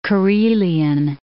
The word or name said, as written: Karelian Isthmus